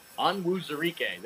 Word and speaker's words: Onwuzurike: Onwuzurike.